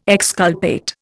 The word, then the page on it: exculpate: Indian